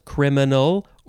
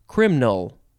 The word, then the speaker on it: criminal: You could have it be three syllables, criminal, or two syllables, criminal.